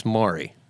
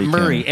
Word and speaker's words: Maury: And you know, I had the same problem for years because where I grew up in Pennsylvania, Maury was Maury. – Yeah, it's Maury. – This isn't the Maury Povich But everybody that lives in Tennessee calls it Murray. M-U, as if it's spelled M-U-R-R-A-Y.